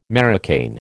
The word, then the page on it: marocain: American English